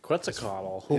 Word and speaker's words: Quetzalcoatl: Foot sticking up, a flying Quetzalcoatl snake. – Quetzalcoatl. – Is flying through. Nice. Dude, like, I can't. – That was flawless execution on his part with that pronunciation.